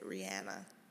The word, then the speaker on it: Rihanna: I have to change the way I say her name, because that's how you say it. Rihanna.